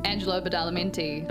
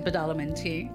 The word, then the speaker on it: Angelo Badalamenti: Angelo Badalamenti. – Badalamenti.